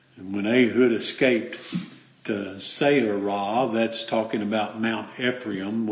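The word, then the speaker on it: Seirath: And I believe that's pronounced Seirah.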